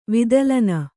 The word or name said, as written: ವಿದಲನ